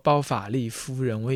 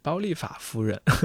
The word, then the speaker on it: 包法利夫人: 呃，但是我觉得很多时候我们都会念错字，比如说包法利夫人，我以前读成是包利法夫人，还有一些其他的一些名字，偶尔也会念错。